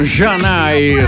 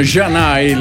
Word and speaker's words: Zanè: Stiamo ricordando il 1994 con Janai, scritto Zanè, che non ha nulla a che fare con la ridente località dell'Alto Vicentino, ha scritto Zanè, però insomma Janai, la pronuncia esatta è questa, 1994, Hey Mr. DJ.